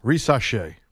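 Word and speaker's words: Risacher: How do you pronounce the last name of the first pick of the draft? – Risache.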